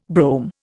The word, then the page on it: brome: Indian